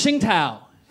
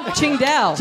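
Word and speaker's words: Tsingtao: And the other one is, it's pronounced Ching Tao. Is that his beer? – Ching Dao.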